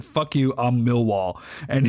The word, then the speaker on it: Millwall: Anyway, recently, there's this football fan from Millwall. Am I saying that correctly? Millwall? – Millwall, yeah.